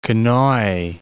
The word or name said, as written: Konoe